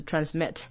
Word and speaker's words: transmit: 然后 that transmit 那个 t 发一个音就好了，第一个 t 不用发音，that 的那个就是 t 结尾不用发音，that transmit 的话，这个字重音在 mi 就是后面的 mit 的部分，transmit 不是 transmit 就是 transmit messages as well as messages 这边的话，messages 的 a 是轻音，就是可能有些人会念太重，就是 messages。